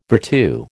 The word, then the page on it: virtu: American English